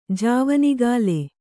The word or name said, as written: ಜಾವನಿಗಾಲೆ